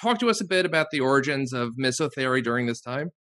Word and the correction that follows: misothery: Yes, I think it should be pronounced misothery, sort of rhyming with misogyny.